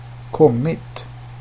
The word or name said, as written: kommit